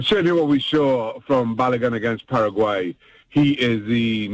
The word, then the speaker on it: Balogun: There's a young man, I believe you pronounce his name, Falaron Balogun. Did I say that right, or am I messing up that name? – Balogun, you got it right, Myron.